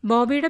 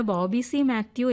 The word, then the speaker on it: ബോബി സി മാത്യു: മാത്രമല്ല, ചിലപ്പോഴൊക്കെ ഉച്ചാരണം ബാബു എന്നും കേൾക്കുന്നുണ്ട്. അതുകൊണ്ട് വായിക്കുന്നത് ബോബി സി മാത്യു എന്ന് പൂർണ്ണമായും പറയണമെന്നാണ് മിനി കേസ് പറയുന്നത്.